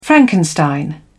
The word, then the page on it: frankenstein: British English